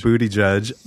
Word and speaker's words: Buttigieg: And now we have Andrew Yang and Pete Buttigieg. – Booty Judge on the debate stage. – The first openly gay presidential candidate that's legitimate. First syllable of his last name is butt.